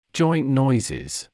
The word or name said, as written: joint noises